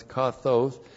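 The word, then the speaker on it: kathos (καθώς): K-A-T-H long O-S, kathos. And kathos indicates comparison and is correctly translated just as or even as.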